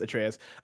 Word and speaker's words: Atreus: I do know his name is pronounced Atreus.